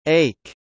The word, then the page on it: ache: éik; エイク